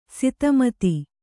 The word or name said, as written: ಸಿತಮತಿ